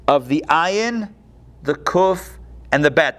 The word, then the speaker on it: Ayin: Well, if you were to write out all three letters, Ayin is written out, the Ayin is one letter, is one character, but the way you pronounce it is A-Y-N, Ayin, so it's comprised of three letters, the Ayin, the Yud, and the final Nun.